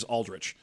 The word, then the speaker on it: Aldrich: I have actually had people, because we don't usually talk about this on the show, but my last name is Aldrich, spelled like A-L-D-R-I-C-H, right?